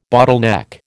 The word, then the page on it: bottleneck: American English